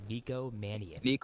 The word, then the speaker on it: Nico Mannion: Next, point guard you would draft first, Cole Anthony or Nico Mannion? Did I say that correctly? Nico Mannion.